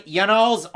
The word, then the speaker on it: Yanal: And yes, we know his first name, what it rhymes with, flannel. It's Yanal.